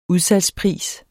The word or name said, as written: udsalgspris